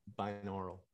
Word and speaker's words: binaural: Binaural.